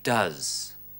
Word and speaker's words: does: Achten Sie hier auf die Aussprache. Es heißt he goes, aber he does.